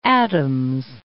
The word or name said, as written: Adam's Bridge